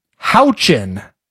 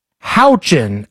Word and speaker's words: Houchin: Houchin, as in Indiana's Aaron Houchin. – Do I need to use... Can you use it in a sentence or? – Aaron Houchin is how you pronounce this woman's name.